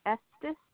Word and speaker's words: Estes: Estes. – How do you spell that? – E-S-T-E-S.